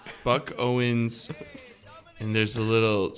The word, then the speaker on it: Buck: That song was by Book O Owens. – Let's go back. Say it as if it rhymes with duck. – Duck. Buck.